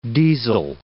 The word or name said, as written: diesel